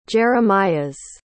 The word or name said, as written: jeremias